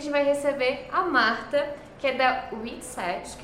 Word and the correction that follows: Witseed: que eu não posso falar errado, WITSET. – WITSEED. – WITSEED. Ah, foi quase. Eu, te, eu tenho esse problema com a pronúncia do E do inglês.